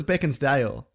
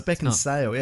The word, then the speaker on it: Beckinsale: Anyway, Kate Beckinsale. – Yes. – I always thought it was Beckinsdale, but no, it's Beckinsale, yeah.